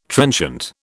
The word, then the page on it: trenchant: British English